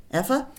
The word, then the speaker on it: ephah: She beat out what she had gleaned, and it was about an ephah. How would you say that? Ephah of barley? – Yes, ephah or an ephah, yes, yeah.